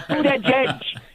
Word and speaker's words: Buttigieg: I love the way Trump would explain to people how to pronounce Buttigieg. It's Buttigieg. Edge, edge. You got to say edge, edge.